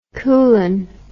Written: kulan

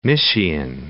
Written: Mysian

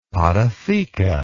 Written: podotheca

